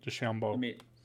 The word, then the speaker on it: DeChambeau: I think it's DeChambeau. – Let's find out. – DeChambeau. It's DeChambeau. Sorry.